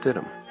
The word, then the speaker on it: Stidham: Monday, in the least surprising announcement in the history of Auburn football, Coach Gus Malzahn announced that Jarrett Stidham, and it is pronounced Stidham, will be the quarterback of Auburn.